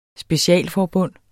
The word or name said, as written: specialforbund